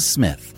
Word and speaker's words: Psmith: I hope you have enjoyed this unabridged production of Leave It to Smith, The P is Silent, Part 4 of 10 by P.G.